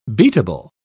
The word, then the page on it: beatable: British English